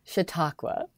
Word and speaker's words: Chautauqua: Chautauqua? – Chautauqua.